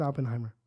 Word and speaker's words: Oppenheimer: It's Oppenheimer. – Mm. – Oppenheimer. Oppenheimer. Stop. – Are you sure? – Yes, I'm sure. – You're Oppenheimer.